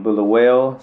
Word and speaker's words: Bulawayo: She's from Bulawayo, Zimbabwe.